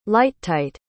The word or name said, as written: lighttight